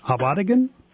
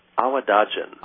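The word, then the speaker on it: Awadagin: Is it Abadigan? – Awadajan.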